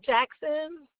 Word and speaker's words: Jackson: Jackson. – J-A-C-K-S-O-N.